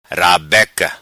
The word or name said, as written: rabeca